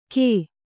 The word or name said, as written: ಘೇ